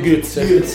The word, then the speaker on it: Götze: Mario, la pronuncia giusta, Mario Götze.